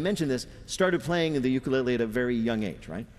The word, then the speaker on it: ukulele: Peter's first question to Taimane, how the hell do you pronounce ukulele? – Ukulele. – Ukulele. – Uku. – Uku. – Lele. – Lele. – You got it.